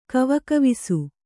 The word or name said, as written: ಖವಖವಿಸು